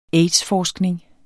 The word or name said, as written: aids-forskning